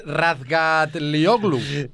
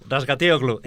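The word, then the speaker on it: Razgatlıoğlu: es Razgatlioglu. Razgatlioglu.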